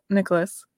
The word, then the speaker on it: Nicolas: Nicolas is my pen name, right? So, and it's spelled N-I-C-O-L-E, so my last name is spelled without the H.